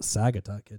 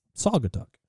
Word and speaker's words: Saugatuck: No one from Michigan would pronounce it Saugatuck. It's Saugatuck. – Saugatuck. – Saw, like a saw.